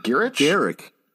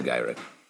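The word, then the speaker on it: Gyrich: Rogue is going ham trying to track down Bolivar Trask, and Henry Gyrick is apparently how you pronounce his name. Another well, big surprise for me. Gyrick. – Oh, yeah. I said Gyrick.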